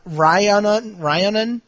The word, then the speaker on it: Rhiannon: Ryanon from Scotland? – Correct. – R-H-I-A-N-N-O-N from Scotland.